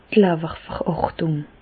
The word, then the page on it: d' Lawwerverhärtùng: Bas-Rhin; Reichshoffen